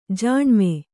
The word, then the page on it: ಜಾಣ್ಮೆ: Kannada